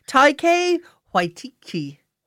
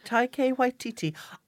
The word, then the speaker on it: Taika Waititi: Taika Waititi. Taika Waititi. I love him. Rita Ora is a lucky woman. This week's movie is Next Goal Wins, and I really do love Taika Waititi.